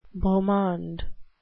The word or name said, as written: beau monde